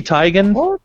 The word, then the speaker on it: Teigen: So Chrissy Teigen? – Yeah, that's how you pronounce the last name. That's how you pronounce the last name, yeah.